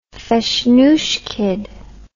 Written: feshnushkied

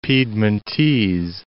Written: Piedmontese

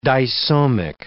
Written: disomic

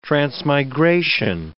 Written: transmigration

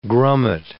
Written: grummet

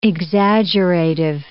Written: exaggerative

